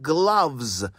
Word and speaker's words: gloves: But it's gloves, like doves, not gloves like loaves.